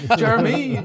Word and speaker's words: Jeremy: I just hear this voice and shouted my name, Jeremy spelled with a Y. Jeremy.